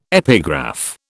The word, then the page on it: epigraph: British English